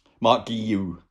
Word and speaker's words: Marc Guiu: Um, presumably, you're talking the, the, one you'll be talking about will be will be um, Mark Gue. Marc Guiu. Guiu. There's been lots of debate about how to say his name, basically because essentially because it's a Catalan name, but you actually say it the same as you would in Spanish.